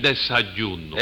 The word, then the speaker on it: desayuno: Sí, yo era el que le llevaba el desollino a los enfermos. – El desayuno. – El desayuno. – Desayuno se dice. No sabe decirlo. – ¿Eh? – No sabe decir desayuno.